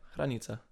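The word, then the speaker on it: Hranica: Yeah, that's how you say it. Well, it's a Polish last name. – Really? – Yeah, Kranica.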